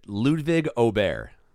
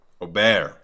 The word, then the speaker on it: Ludvig Åberg: Ludvig Åberg. – Close. – 19-1. – Close. – School me. – I've heard it most often said Ludvig Åberg. – Åberg. Okay. – Åberg.